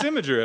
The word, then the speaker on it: Imgur: It's Imgur, everybody.